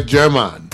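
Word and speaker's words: Germán: German, because it's the G and then the J are not pronounced the same. So right. – It actually is a German, German Garmendia. Hola soy German.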